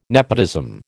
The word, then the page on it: nepotism: American English